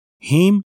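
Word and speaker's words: hymn: هیم ان ان آخرش تلفظ نمیشه.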